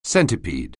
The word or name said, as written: centipede